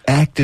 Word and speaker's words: ACT: He goes, Act is pronounced as a word, not letters.